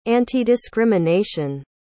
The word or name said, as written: antidiscrimination